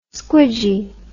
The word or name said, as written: squidgy